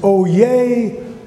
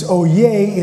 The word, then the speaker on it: oyez: And he would say these words, O yay, O yay, O yay. And it's spelled O-Y-E-Z. We would say in English, oh yes, but it's oh yay in French. It's a French word.